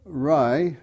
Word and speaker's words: Rae: Well, Ray is spelled R-A-E, and that was after my grandmother's surname. And that's why it's more a girl's name, Ray.